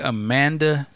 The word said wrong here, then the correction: Amandla: Amandla, there's an L.